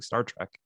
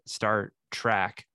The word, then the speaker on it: Star Trek: Strangely, he makes the rare mistake of mispronouncing Star Trek as he pronounces it Star Track.